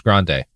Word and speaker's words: Grande: Sophie, it's pronounced Grande.